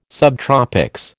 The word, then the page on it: subtropics: American English